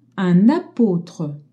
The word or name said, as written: apôtre